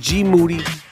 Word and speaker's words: Moody: And Saturday, August 25th, right in Boston, Massachusetts, the I Am Rappaport Stereo Podcast World Tour, me and G Moody, whose last name rhymes with duty, live, taking on the entire world.